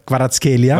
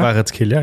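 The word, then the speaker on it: Kvaratskhelia: mit dem äh, Quitscher Quaratskelia. Quaratskelia, ja.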